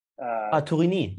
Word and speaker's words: Tourigny: What do you think of, I don't know how to say it because it's French, Tournier? – Tourigny. Yeah. Miguel Tourigny.